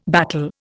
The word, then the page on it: battle: Indian